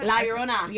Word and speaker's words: La Llorona: It's like La Llorona. – La Llorona.